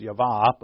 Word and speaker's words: Yahweh: It's my preferred pronunciation is Yavah, but...